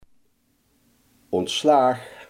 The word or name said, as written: ontslag